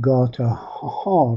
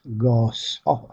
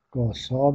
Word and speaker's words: گاسا: در قدیم گاتاها می گفتن که لفظ قدیم است، چون خود اوستا و کهن‌ترین بخش اوستا رو در نظر بگیریم تلفظش گاسا است. گاسا با سه نقطه، گاسا. بنابراین گفتن گاتا و گاتاها جایز نیست امروز، یا باید بگیم گاسا یا گاهان، همونطوری که خود معبدان زرتشتی و همینطور در متون پهلوی گاهان به کار رفته، متون گاهانی.